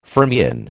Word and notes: fermion: American English

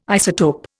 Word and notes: isotope: Indian

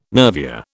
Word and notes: nervure: British English

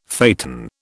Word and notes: phaeton: British English

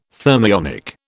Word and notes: thermionic: British English

